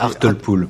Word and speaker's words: Hartlepool: Hartlepool. – Hartlepool, donc, qui est un singe déguisé en Napoléon, on va dire.